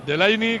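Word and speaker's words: Delaney: Sí, Delaini, que además se dice así. Lo acaba de comentar Miguel Ángel, nuestro amigo de Copenhague, Delaini.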